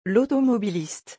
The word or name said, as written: l'automobiliste